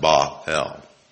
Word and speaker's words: Baal: The name of the God of Babylon was Bel, B-E-L, meaning Lord or Master. In the Hebrew name, the Hebrew pronunciation of that is Ba-al, Ba-el, B-A-A-L, but pronounced Ba-el. Bel was the son, god, and husband of Astoret, or Ishtar. Now, it's spelled I-S-H-T-A-R, but in Hebrew, you know, the H isn't pronounced.